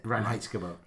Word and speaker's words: Reinheitsgebot: Reinheitsgebot.